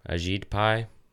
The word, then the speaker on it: Ajit Pai: Is that how you say it? I thought it was Ajit. – Ajit Pai.